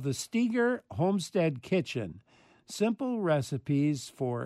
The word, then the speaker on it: Steger: And by the way, Steger is spelled S-T-E-G-E-R. So there's only pronounced Steger, but it's with one E, S-T-E-G-E-R.